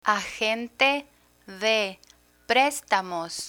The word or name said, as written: agente de prestamos